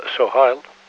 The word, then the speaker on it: Sohail: Maar omdat ze die L en de S een beetje moeilijk vinden om uit te spreken, laten ze de L maar weg. Dus dan wordt het Sohail.